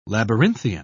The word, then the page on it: labyrinthian: British English